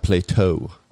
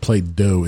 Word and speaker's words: Play-Doh: It's though, not Play-Toh. – Oh. – It's a soft T. Play-Doh. – I think Play-Doh is a D, actually. – D-O-H.